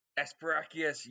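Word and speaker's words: Esperacchius: I don't know if I'm pronouncing it correctly. Esperachius. – Esperachius, yes.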